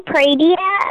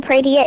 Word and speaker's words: Pradia: Facebook at Ayani Pradia. Ayani Pradia, it's spelled with an I. It's I-Y-A-N-N-I. Pradia is spelled P-R-A-D-I-A.